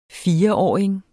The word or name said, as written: fireåring